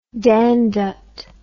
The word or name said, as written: dangdut